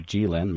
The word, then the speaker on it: Jilin: Earlier in the broadcast, we were talking about Jilin University, and the dean's office here on campus called me and said, hey, it's Jilin. Make sure you pronounce it correctly.